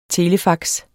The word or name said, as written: telefax